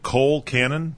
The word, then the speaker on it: colcannon: Coal cannon? – Coal cannon. – Spell that for us. – C-O-L-C-A-N-N-O-N.